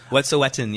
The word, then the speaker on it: Wet'suwet'en: It's called the Wet'suwet'en. – Wet'suwet'en, yes.